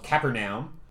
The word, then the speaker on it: Capernaum: We have a Best Foreign Film nominee called Capernaum, and Lee saw it, said it was really great. – I'm impressed that you pronounced it correctly the first time.